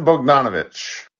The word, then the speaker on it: Bogdanovic: Bogdanovic.